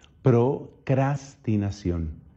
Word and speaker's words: procrastinación: Debo confesar aquí en público que durante mucho tiempo yo pronunciaba mal esa palabra, entre otras cosas porque es raro encontrar esas eres tan seguidas. La pronunciación correcta, ahora lo sé, es procrastinación.